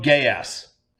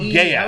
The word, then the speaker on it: Geass: Yeah, code gay ass. How is this spelled? – Yes. G-E-A-S-S. Geese? Code gay ass. Yes. Code gay ass. That's how you say it? Yes.